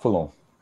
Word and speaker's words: Foulon: Christophe Foulon.